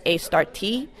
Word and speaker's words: Astarte: So actually the correct pronunciation would be probably something more like A Start T,